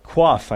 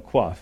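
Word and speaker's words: Qoph: Our next letter here is the word coif, I think it's pronounced. Not like a hairstyle coif, but, you know, coif.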